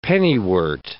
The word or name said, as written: pennywort